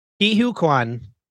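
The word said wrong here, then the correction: Ke Huy Quan: Ki-Hoi, it's Ki-Hoi Kwan.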